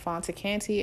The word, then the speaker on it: Fanta Kante: If you want to follow me on Facebook, follow me at Fanta Kante, and it's spelled F-A-N-T-A, last name K-A-N-T-E.